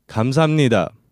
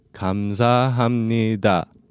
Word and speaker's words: 감사합니다: In Korean, thank you is 감사합니다. And one more time slowly, 감사합니다. Let's break this down by syllable. 감사합니다.